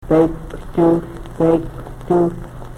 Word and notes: pekwtuhl: Yurok

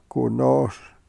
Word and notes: ku we-nos: Yurok

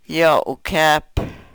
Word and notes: yo u-kep: Yurok

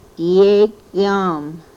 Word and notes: yegom: Yurok

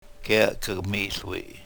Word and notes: kel ke-mihl wi: yurok

